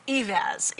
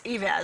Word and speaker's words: Eivaz: Yeah, it's Eivaz. Eivaz.